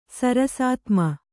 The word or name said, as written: ಸರಸಾತ್ಮ